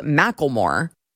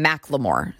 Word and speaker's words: Macklemore: Now, I was just recently informed that it's spelled Macklemore, but you're supposed to call him Macklemore. So it sounds ridiculous, but that's his name.